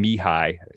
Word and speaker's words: Mihai: I think it's, you pronounce the middle name Mihai. So Emil Mihai Cioran.